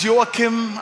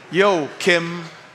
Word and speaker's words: Joakim: I tell U.S. people that if you just try to imagine a rapper greeting the North Korean dictator, Joakim, that will bring you in the vicinity of how my name is pronounced.